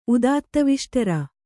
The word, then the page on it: ಉದಾತ್ತವಿಷ್ಟರ: kannada